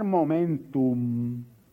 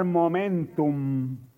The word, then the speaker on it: momentum: Ahí, en ese tiempo, en ese singular momentum, no con O, con M ahora lo pronuncio, en ese singular momentum, es que la tierra comenzará a ser llena del conocimiento de Dios, como las aguas cubren la mar.